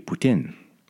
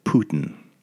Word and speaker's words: poutine: And I was thinking like, well, a lot of people in Quebec will say poutine, which I was trying to write in my best words, how you would say that phonetically.